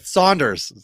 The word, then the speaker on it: Saunders: Must have been on the Michael Sanders sprinkler. – Saunders, say his name right. Saunders, Michael Saunders.